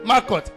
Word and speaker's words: Malkuth: Marcot. M-A-L-K-U-T-H. Marcot. Marcot. Hallelujah. It is Marcot.